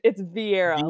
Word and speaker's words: Virreira: It's Virreira. Like Meredith Virreira, Melanie Virreira.